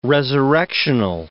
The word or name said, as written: resurrectional